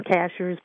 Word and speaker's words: Cashiers: And it's pronounced Cashiers, but it's like gotcha. – Somebody that works at Walmart's.